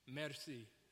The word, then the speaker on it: merci: He says, in France, we say merci. How many people are taking French in here? Merci. Is that how you say it? Yeah, there you go.